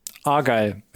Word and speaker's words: Argyle: Argyle, würde ich jetzt mal aussprechen. – Argyle, ja.